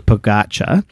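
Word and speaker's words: Pogačar: Now, Tadej Pogacar, which rhymes with focaccia, from Slovenia, has won his fifth Tour de France.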